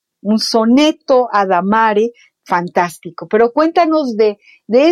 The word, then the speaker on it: adamare: De latín adamare, con acento en la A, cortejar, requebrar.